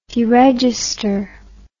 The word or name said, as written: deregistration